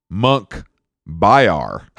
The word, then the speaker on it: Munkhbayar: His name, Monkey Bar. – No. Monk Bayar. Hold on, let me spell it. Go back. For you guys at home, it's MUNKHBAYAR.